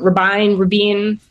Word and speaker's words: Rabine: I always say his name wrong. Rabine.